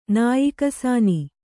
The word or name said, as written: ನಾಯಿಕಸಾನಿ